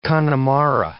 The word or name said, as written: Connemara